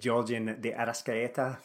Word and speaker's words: Giorgian De Arrascaeta: Uh, Giorgian De Arrascaeta from.